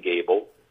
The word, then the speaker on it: Gable: So if you didn't catch it, my name is Ryan Gable. And my last name is spelled like the actor Clark Gable. It's G-A-B-L-E.